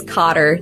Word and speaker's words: Cotter: It's Cotter. C-O-T-T-E-R. K-O-T-T-E-R.